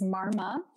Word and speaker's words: Marma: It's Marma. So M-A-R-M-A.